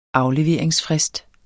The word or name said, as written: AFLEVERINGSFRIST